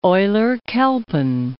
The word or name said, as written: Euler-Chelpin